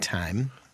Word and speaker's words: thyme: Why do you think it's T-H-Y-M-E and we pronounce it thyme? And it's like, where's the H in this? – Yeah. – Why don't we pronounce that? – How many English words in your dictionary?